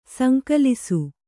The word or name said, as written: ಸಂಕಲಿಸು